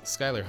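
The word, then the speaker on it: Schuyler: My name is Skylar Hunt. You can follow me on Twitter and Instagram, at Skylar ID. You spell that S-C-H, Uniform Yankee, L-E-R.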